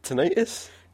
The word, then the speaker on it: tinnitus: How did you pronounce it? – Tinnitus.